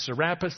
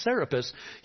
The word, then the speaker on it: Serapis: Serapis, maybe you've heard him pronounced Serapis. That's incorrect. It's Serapis.